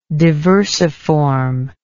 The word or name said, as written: diversiform